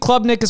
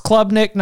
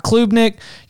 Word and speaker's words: Klubnik: Hey, new guy, I believe uh, Klubnik, I believe Klubnik is pronounced Klubnik, not Klubnik.